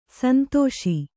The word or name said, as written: ಸಂದೇಶ